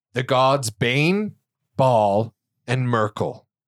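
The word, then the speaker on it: Myrkul: It's M-Y-R-K-U-L. Yeah, bring that cut on. – And I looked up the pronunciation and it's Merkel.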